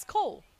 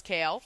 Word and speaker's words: Kale: All 8 of these books, I pronounce his name as Cole. – You did. – Yeah, Cole. – You did. – I mean, that is just what it looks like to me. Shout out to Sky. Sky's Library. LOL. Who loves Kale. Informed me that I was pronouncing it wrong.